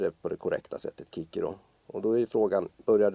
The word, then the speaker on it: Cicero: Väldigt välkänd gammal författare som alltid har uttalats Cicero i Sverige. Men om man ska vara korrekt så heter han egentligen Kikero. C ska egentligen uttalas som K.